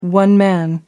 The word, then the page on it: one-man: British English